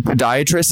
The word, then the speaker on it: podiatrist: Did I pronounce that right? Is it podiatrist? Is that how you say that? – Yes, perfect. Yep.